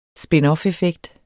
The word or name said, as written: spinoffeffekt